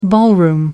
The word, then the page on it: ballroom: American English